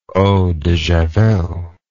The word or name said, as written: eau de Javelle